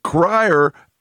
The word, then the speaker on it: Cryer: But your Connecticut band, Cryer, I'm going to spell it. Tell me if it's right. C-R-Y-E-R? – Yes. – Cryer.